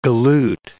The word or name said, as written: galoot